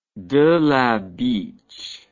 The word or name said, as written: De la Beche